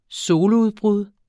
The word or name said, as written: soludbrud